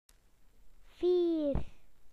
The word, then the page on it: proud: British English